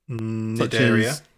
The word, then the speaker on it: Cnidaria: Cnidaria. – Cnidaria. – The sea is silent. – Silent sea. I don't know why the sea's silent.